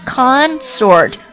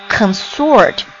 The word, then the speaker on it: consort: 这个单词读作 consort，它作为名词呢是表示这个一般指统治者的这个配偶， the husband or wife of a ruler，这它名词的意思啊。你看它读音名词的时候是 consort，但是它作为动词的时候就变成了 consort，表示厮混、鬼混。